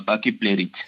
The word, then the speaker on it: pachypleurite: C'est de la pachypleurite. – Pachypleurite ou pachypleurite, je ne connais pas forcément la prononciation. C'est quoi? On fait comme on veut? – Oui, c'est un peu comme pachyderme.